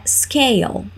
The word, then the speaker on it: scale: Balança é scale. Repeat, scale. Nota a pronúncia dessas palavras que começam com S. É comum a gente querer colocar um I antes dessas palavrinhas.